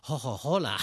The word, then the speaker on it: Coca-Cola: Ho sentito un'espressione, invece di dire Coca-Cola, si dicono ho-ho-hola. – Aspirano la C.